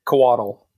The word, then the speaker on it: Coatl: Coatl, yeah.